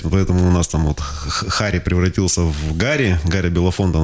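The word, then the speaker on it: Harry: Человек, которого можно, если правильно произносить его имя, то можно немножечко почувствовать себя украинцем. Потому что это Харри Белафонте.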